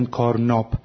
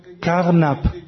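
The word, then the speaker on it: Carnap: اونها یه جورایی بین آ و ا تلفظ میکنن، مثلا نمیگن کارناب، میگن کارنب.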